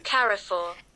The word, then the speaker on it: Carrefour: Hey Siri, can you please pronounce for us Carrefour in British pronunciation? – Hapana, nyi mko na Google. – She's... – in British pronunciation again, Siri. – Shut up. – I'm sorry, Siri. They were making so much noise. Can you please pronounce for us Carrefour in British pronunciation? – Carrefour. – And in American pronunciation, Siri, American? – Carrefour.